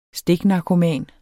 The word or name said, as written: stiknarkoman